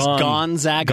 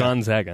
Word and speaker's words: Gonzaga: I'm glad that you say it correctly. We learned that on Friday. – And it is Gonzaga. – Gonzaga.